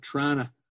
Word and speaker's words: Toronto: I pronounce it Trana, T-R-A-W-N-A.